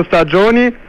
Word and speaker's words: Stagioni: 6 Quattro Stagioni? – Stagioni. – Wir haben nur Stagionis, nicht Stagionis. – Nein, Stagioni, schreibt man G-I-O-N-I.